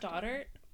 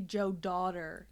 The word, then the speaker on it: Stoddart: Stoddart. – S-T-O-D-D-A-R-T. I thought you said Cassie Jo daughter. – No, Cassie Jo Stoddart.